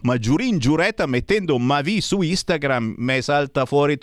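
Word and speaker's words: Mavì: di Mavi con l'accento sulla I intitolata Nagasaki.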